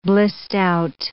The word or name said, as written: blissed-out